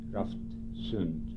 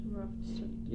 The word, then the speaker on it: Raftsund: How do you spell that? – R-A-F-T-S-U-N-D, Roftsund. – Roftsund.